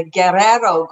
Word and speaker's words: Guerrero: It's the Guerrero Gold Belt. And so Guerrero.